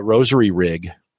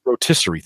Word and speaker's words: rotisserie: So, Mark just said he just picked up a rosary rig. Wait, is that, how do you pronounce that? – Rotisserie.